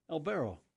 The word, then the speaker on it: Albero: A-L-B-E-R-O. – El Barro.